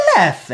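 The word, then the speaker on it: Leffe: but I believe the pronunciation is Lef.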